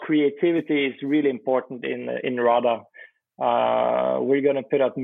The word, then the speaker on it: hrada: RADA is the name of our new project. RADA. – You might have to spell that one. – Yeah. It's H-R-A-D-A. H-R-A-D-A. It's an RADA.